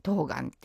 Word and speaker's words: 冬瓜: でも収穫した後切らずにずっとそのまま置いといたら冬まで持つっていうことで、冬の瓜と書いて冬瓜って読むっていうのは、